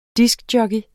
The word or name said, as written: diskjockey